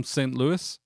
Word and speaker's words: St. Louis: And then I had a friend from St. Louis. But you don't really pronounce the S.